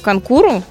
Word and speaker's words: конкуру: А к началу 20 века практически все европейские страны проводили у себе национальные соревнования по конкуру. Правильно же ударение поставила? То вроде как так. – Все правильно.